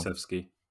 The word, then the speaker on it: Kulusevski: As a team, how do you pronounce his name again? Because I always get it wrong. – Kulusevski. – That's the one.